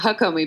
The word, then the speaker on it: Hakomi: Haikomi methods? Yeah, it was almost perfect. Somatica was perfect. It's Hakomi, but yeah, yes, okay.